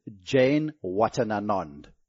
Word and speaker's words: Janewattananond: to get him on camera pronouncing the thing. And so it's Jane Watanana. – Jane Watananan.